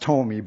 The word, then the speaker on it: Ptolemy: There's a P in front of it. It's pronounced Ptolemy, but I'm not going to tell you again how to pronounce it.